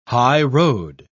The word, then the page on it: highroad: British English